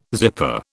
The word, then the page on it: zipper: British English